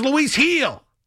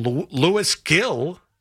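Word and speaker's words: Luis Gil: His name is Lewis Heel. If you're a Yankee fan and you think Lewis Heel is going to be the next Mariano Rivera, then know how to pronounce the man's name. Not all that difficult. It really isn't. Like, but I hear so many Yankee fans call up and call him. They don't call him Lewis Gill.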